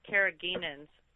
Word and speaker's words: carrageenans: It looks like it's spelled carrageenans. – That's it exactly. That's it. That's the ingredient. Yep. That's it. – And I don't know if I'm saying it properly, but that sounds right. That's absolutely it.